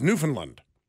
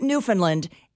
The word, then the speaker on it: Newfoundland: One of the things the locals said was that everyone pronounces it Newfoundland, and it is their biggest pet peeve. They want it to rhyme with hand.